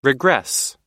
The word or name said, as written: regress